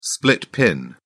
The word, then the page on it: split pin: British English